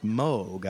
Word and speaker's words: Moog: And it is pronounced Moog, I have found out. – That's what I found out as well.